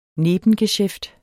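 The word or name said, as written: NEBENGESJÆFTER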